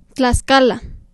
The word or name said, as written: Tlaxcala